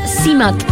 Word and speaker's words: CMAT: El Si la vas a buscar en Spotify, tenés que poner C-M-A-T, porque se llama CIMAT, que es la abreviación de Ciara Mary Alice Thompson, que es su nombre.